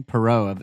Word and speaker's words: Poirot: Poirot.